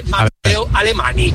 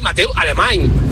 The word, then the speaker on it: Alemany: ¿Le podéis decir a vuestros colaboradores y tertulianos que no se dice Mateo Alemany? Se dice Mateo Alemany, como si acabaras con ñ.